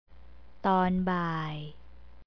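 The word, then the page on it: ตอนบ่าย: tonbai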